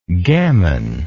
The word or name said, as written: gammoner